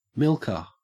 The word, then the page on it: milker: Australian English